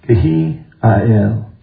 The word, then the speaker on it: Hiel: Now, Hiel, what that name means, and it's actually pronounced Kehi-Ael.